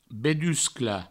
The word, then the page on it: bedusclar: Provençal; beduscla